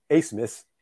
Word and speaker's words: Abmas: So Max Asmus, and it's the last name is spelled A-B-M-A-S, but it's pronounced Asmus.